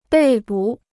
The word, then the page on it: 被捕: bèi bǔ